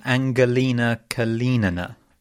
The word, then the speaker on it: Angelina Kalinina: I think it's a hard G in the first name. Angelina Kalinina, I believe.